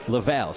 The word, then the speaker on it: Laval: Laval.